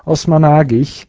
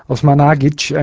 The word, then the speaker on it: Osmanagić: Sam Osmanagić, Osmanagić eigentlich. Ich habe den Namen immer ein bisschen falsch ausgesprochen.